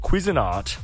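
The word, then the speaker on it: Cuisinart: We have a Quizinart.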